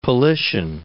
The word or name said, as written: Politian